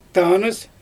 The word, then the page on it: taanɯs: Xyzyl